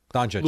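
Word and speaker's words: Dončić: Doncic? – Yeah, Doncic.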